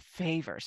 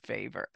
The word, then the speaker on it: Vijver: Before we started today, you and I were having a conversation, because your name is spelled V-I-J-V-E-R, but it's pronounced Favor.